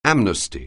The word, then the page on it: amnesty: anglais